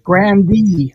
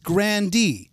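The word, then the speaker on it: Grande: Grandee. – Well, now, wait a second. Is it true that it's just Grandee? Yeah, you do pronounce that E.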